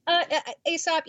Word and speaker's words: Aesop: So you were at Multi-Access Games, and then it sounds like, yeah, you went to, is it Aesop? Am I saying that right? Aesop Games? – Aesop, it's the Fables guy, you know.